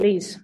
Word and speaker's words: Liis: Yes, it's Liis. It's like Liis, okay, great. – Estonian version.